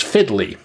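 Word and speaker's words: FDLI: She is an elected member of the American Law Institute and part of the leadership of the Food and Drug Law Institute that I understand we pronounce as Fiddly, which has always made me laugh for some reason.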